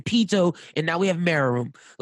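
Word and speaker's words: Meruem: I understand Bisky may be a fun character, but if you're going to put Meru, how do you say his name? – Merum.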